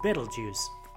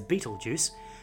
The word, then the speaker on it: Betelgeuse: And that red star I mentioned just then, that star is called Betelgeuse, often mispronounced as Betelgeuse, and that name loosely translates from Arabic as Shoulder of Orion.